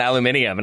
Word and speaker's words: aluminium: And he was insistent on aluminium. And I was like, dude, there is not an extra I in there.